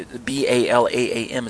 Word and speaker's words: Balaam: And so what he does is he hires the top pagan prophet of his day, which is Balaam, Balaam. I think you, you B A L A A M is how it's pronounced in um, English circles, but Balaam is actually his name in Hebrew.